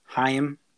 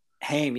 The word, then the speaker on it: Haim: That's how you pronounce it. Haim. – Wow. This whole time I've been saying Haim. – Haim, exactly. It's their last name. So they're all sisters in a band, and it's Haim. They're obviously from. It's a European name, and they said it, and I was like, "Oh, that's how you said it."